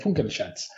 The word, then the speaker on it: Funkelschatz: Bueno, otro de los nominados es el Funkelschatz. Doctor, por favor, ¿cómo pronunciamos? – Sí, muy bien. Funkelschatz.